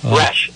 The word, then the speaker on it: Resch: And now the proper pronunciation is Resch? – Resch, R-E-S-H.